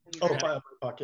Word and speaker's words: BIPOC: BIPOC, yeah. – How do you say it? – BIPOC? I think that's BIPOC. BIPOC. So he's already very involved in that for years and doing events.